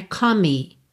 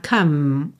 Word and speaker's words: come: Come, outra palavra que tem o E final mudo, então não é come, tá? Come, o som termina no M.